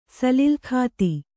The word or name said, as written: ಸಲಿಲಖಾತಿ